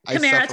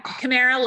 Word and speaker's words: Kamara: Kamara Toffolo. Yeah. So, yeah, Toffolo. It is tough, isn't it? Yeah. Kamara, like Tamara, or like Kamaro Toffolo.